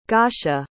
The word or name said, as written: gasha